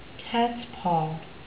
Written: cats paw

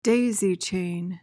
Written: daisy-chain

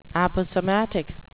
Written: aposematic

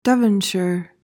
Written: Devonshire

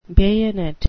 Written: bayonet

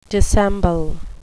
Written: dissemble

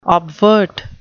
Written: obvert

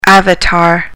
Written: avatar

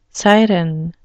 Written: siren

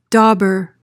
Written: dobber

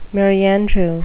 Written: merry-andrew

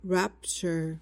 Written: rapture

